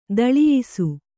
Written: ದೊಲ್ಲಯಿಸು

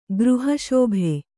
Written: ಗೃಹಶೋಭೆ

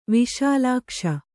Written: ವಿಶಾಲಾಕ್ಷ

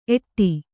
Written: ಏಡ

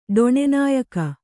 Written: ಡೊಣೆನಾಯಕ

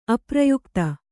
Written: ಅಪ್ರಯುಕ್ತ

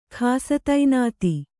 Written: ಖಾಸತೈನಾತಿ

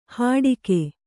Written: ಹಡಿಕೆ

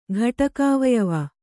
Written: ಘಟಕಾವಯವ

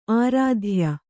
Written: ಆರಾಧ್ಯ

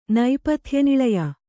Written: ನೈಪಥ್ಯನಿಳಯ

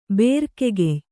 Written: ಬುರುಗುಕಾಯಿ

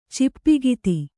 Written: ಚಿಪ್ಪಿಗಿತಿ

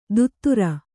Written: ದುತ್ತೂರ

